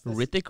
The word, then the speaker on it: Hrithik: The H is silent. – Yes, Hrithik Roshan.